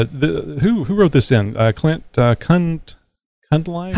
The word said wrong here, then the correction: Cunliffe: Cunliffe. – I think Cunliffe. Josh, I like Josh's pronunciation of Cunliffe.